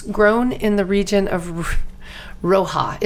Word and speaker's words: Rioja: Rioja. It's spelled R-I-O-J-A. So Rioja.